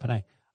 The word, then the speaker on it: Penei: Penei, why did I pronounce it that way? – That's right, Penei.